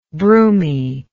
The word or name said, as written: broomy